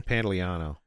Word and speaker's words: Pantoliano: Pantoliano.